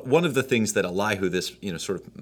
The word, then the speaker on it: Elihu: Elihu, is that how you pronounce his name? – Yes. – Elihu.